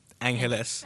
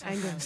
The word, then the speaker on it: Angeles: So it's spelled A-N, it's like Los Angeles, mm-hmm. but remove the Los and it's pronounced Angeles. – Angeles. – It's more Spanish, I would say.